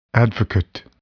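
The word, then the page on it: advocate: noun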